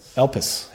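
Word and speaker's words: Elpis (ἐλπίς): Elpis.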